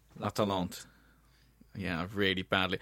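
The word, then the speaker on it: L'Atalante: It's La Talente, listeners. That's how it's pronounced. Not how I said it.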